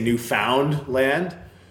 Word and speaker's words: Newfoundland: But when I have this conversation, that's, because it's not pronounced, like, someone from Newfoundland won't, people hit the found too hard. I think that's what it is. All so right. If they say Newfoundland, where, like, I guess if I were to phonetically spell it how I say it, is N-E-W-F-E-N-L-A-N-D, Newfoundland. So Newfoundland. you sort of graze over the middle part of the word, which is, like, speaks true to how most people in Newfoundland operate in life in general.